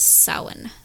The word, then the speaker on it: Samhain: It's spelled Samhain, but it's pronounced Samhain.